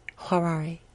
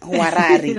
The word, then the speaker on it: Hwarari: Huarari. – Okay. – H-W-A-R-A-R-I. – Let's say that. – Huarari.